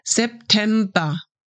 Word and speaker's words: September: Again, we drop the R at the end and pronounce it just like A. September.